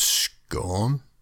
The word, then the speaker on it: Scone: He was crowned at Scone or Scone, but I prefer Scone because that's how it's spelled, and I can detect the bile rising in the mouths of anyone from, you know, Northern Britain.